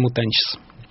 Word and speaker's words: Mutantes: Правильно название этой группы произносится по-разному. Бразильцы из разных регионов могут сказать «Os Mutantes» или «Os Mutantes», вот или нечто среднее между вот этим вот «Teiche». Португальцы произносят вообще по-своему, поэтому произносите, как заблагорассудится. Пишется «Mutantes», я привык произносить «Mutantes».